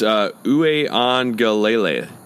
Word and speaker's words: Uiagalelei: DJ Uiagalelei. – It's Uiagalelei. – Uiagalelei.